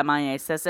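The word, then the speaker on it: myiasis: So, miasis. Did I say that right? – Yeah, miasis.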